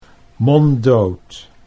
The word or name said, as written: monddood